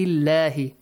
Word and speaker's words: الله: آيات الله. That's a lam in the word Allah is pronounced with a light sound.